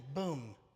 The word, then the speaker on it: Boom: I always say her name wrong. Is Corrie Ten Boom, isn't it? I always put an L in there, call her a Bloom.